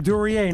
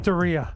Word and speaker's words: Duryea: Well, you mispronounced my hometown. – Duryea, right? – Duryea, not Duryea.